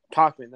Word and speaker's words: Tauchman: And then bench, you got Gardner, Tauchman, Tyler Wade, and the backup catcher. – Talkman, that's right.